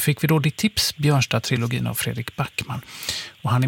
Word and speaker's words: Björnstad-trilogin: Så roligt att du har hittat honom och Björnstad-trilogin, var det rätt uttalat? – Ja, precis.